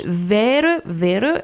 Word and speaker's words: Vere, Vere: Vous nous avez pris un morceau qui s'appelle Vere, Vere. Est-ce que je le dis bien? – Oui, alors c'est de l'estonien, c'est une ballade, une berceuse estonienne de Marianne Nutt, qui est une violoniste et chanteuse.